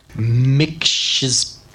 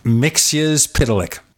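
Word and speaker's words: Mxyzptlk: And I was telling him how to pronounce the name Mr. Mixyzptlk. – Mixyzptlk. – Mixyzptlk. There are actually two versions in Wikipedia of this is the preferred version.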